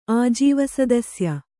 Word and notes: ಆಜೀವಸದಸ್ಯೆ: ājīvasadasye

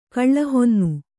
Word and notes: ಕಳಹೊನ್ನೆ: kaḷahonne

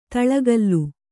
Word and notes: ತುಳಕಲು: tuḷakalu

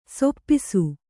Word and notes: ಸಾಪಿಸು: sāpisu

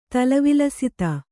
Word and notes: ತಲವಿಲಸಿತ: tala vilasita

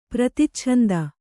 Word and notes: ಪ್ರತಿಚ್ಛಂದ: praticchanda

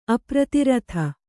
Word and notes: ಅಪ್ರತಿರಥ: apratiratha

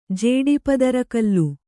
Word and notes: ಜೇಡಿಪದರಗಲ್ಲು: jēḍi padaragallu

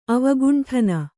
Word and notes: ಅವಕುಂಠನ: avakuṇṭhana

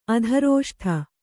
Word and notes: ಅದೃಷ್ಟ: adřṣṭa

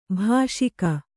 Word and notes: ಭಾಷಿಕ: Kannada

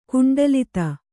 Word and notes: ಕುಂಡಲಿತ: kuṇḍalita

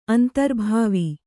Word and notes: ಅಂತರ್ಭಾವಿ: antarbhāvi; Kannada

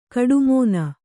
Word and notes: ಕೋಟೆಮನೆ: kōṭe mane

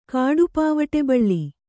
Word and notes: ಕಾಡುಪಾವಟೆಬಳ್ಳಿ: kāḍu pāvaṭe baḷḷi

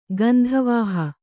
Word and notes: ಗಂಧವಹೆ: gandhavahe

